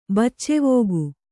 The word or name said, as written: ಬಚ್ಚೆವೋಗು